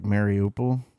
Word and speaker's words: Mariupol: Meropol? Am I saying that correctly? – I've heard it said several ways. Yeah, the most common way is like Mariupol.